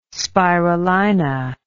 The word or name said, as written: spirulina